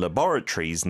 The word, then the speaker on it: laboratories: Although modern laboratories now employ different triggering methods to develop pharmaceuticals, past observations proved invaluable. – 미국 발음은 laboratories. 영어 발음은요? – Laboratories. – 네.